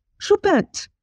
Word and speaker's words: Choupette: Choupette.